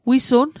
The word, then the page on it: wisun: Mi'gmaq